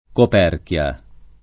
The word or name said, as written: Coperchia